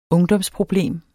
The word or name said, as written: ungdomsproblem